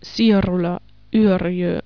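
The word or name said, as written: Sirola, Yrjö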